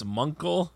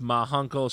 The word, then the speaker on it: Ma Hunkle: So anyway, the three-headed Cerebus, they ask for the password, and Diana says it's Munkle. upon which she is corrected by Alan Scott. It's pronounced Ma Hunkle.